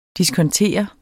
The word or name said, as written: diskontere